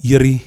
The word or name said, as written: Yiri